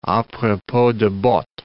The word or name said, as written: a propos de bottes